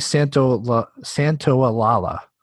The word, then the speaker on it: Santaolalla: This is funny because I chose a song that's not actually by the composer, who is Gustavo Santaolalla, I believe is how it's pronounced. – Santaolalla, I think. – Is it? Well, then it's definitely Santaolalla. Because it's with an L and then an A and then a double L A.